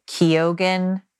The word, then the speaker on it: Keoghan: That's how it's pronounced. That's how he pronounces it, is Keoghan, as per interviews.